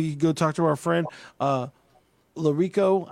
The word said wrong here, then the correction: Elrico: I'm saying his name wrong. Like Elrico. – I say. Elrico.